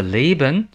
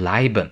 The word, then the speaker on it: leben: 比如，我们先听一位同学有一个小短文，反复录了好几次，但是其中的 leben 总是读成 leiben。